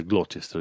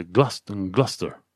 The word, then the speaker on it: Gloucester: Sau știi că se scrie Gloucester, dar nimeni nu, în Anglia nu citește Gloucester, zice Gloucester.